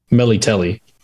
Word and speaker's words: Melitele: But it's not Melitela. It's a different one. – It's not Melitela. It's a different one. Hmm. Or, sorry, my bad. I should say it like they say it in the show. Melitele.